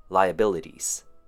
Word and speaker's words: liabilities: And our last one for pronunciation would be liabilities, five syllables. Lie, as in you're lying. Lie, short U sound. B, short I sound. L, kind of sounds like a short U sound. And T's. Try saying the word D's, spelt D-E-E-Z.